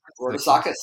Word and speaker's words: Rodousakis: Rodousakis, yeah, pretty close.